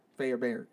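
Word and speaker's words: Fairbairn: Fair Baron. – Sound like Care Bear, but Fair Bear. Fair Bear.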